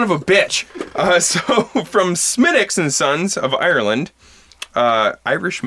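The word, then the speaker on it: Smithwick's: Do you have any Smithwicks? – come on. – Yes, it's spelled Smithwicks, but Mike, you host a podcast about beer. You should know that this is pronounced Smittix.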